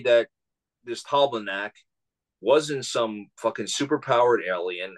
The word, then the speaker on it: Talbaldak: Like, and again, I'm not denying that, like, here, the one that, and say his name, Talbaldak, how do you say it? – Yeah, Talbaldak. – Talbaldak.